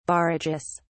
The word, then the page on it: barrages: British English